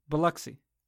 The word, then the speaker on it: Biloxi: Pronounce it like there's a U in it. – Biloxi.